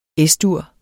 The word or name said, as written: Es-dur